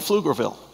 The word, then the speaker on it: Pflugerville: By the body of Christ, maybe I'm not talking about people here in Georgetown, but in Pflugerville. and some of the surrounding, is, is that how you say it? Yeah, this, the P is silent.